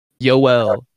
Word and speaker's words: Yoel: Joel Romero? Yoel. Why? Like Joel, but spelled with a Y.